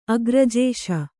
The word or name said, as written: ಅಗ್ರಜೇಶ